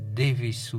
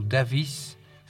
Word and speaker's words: Davis: Et également quelqu'un comme Gary Davis ou Davis, ça dépend si vous le prononcez à l'anglaise ou bien à la française.